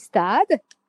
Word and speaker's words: Stade: So you English people tend to mention Stade Français Paris. Stade Français. The correct pronunciation is Stade. Repeat after me. Stade.